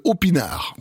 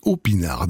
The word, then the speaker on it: Aupinard: C'est l'heure de la découverte avec un artiste qui s'appelle Opinard. Oui, ça s'écrit en un seul mot, A-U-P-I-N-A-R-D, Opinard. Derrière